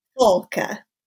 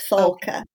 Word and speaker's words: Falke: It's Falke. It's German for falcon. So it's Falke.